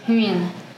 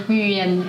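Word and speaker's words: Nguyễn: So my Vietnamese name is Nguyen. And even for Vietnamese people, this name is quite hard to pronounce because you have to, like, your mouth has to turn a lot, like Nguyen, like that.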